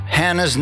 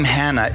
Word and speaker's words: Hannah: Hannah's name, the name Hannah is actually Channah. It looks like China, but the C is silent, so it's actually Hannah. Hannah.